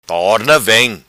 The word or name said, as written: Torna Vem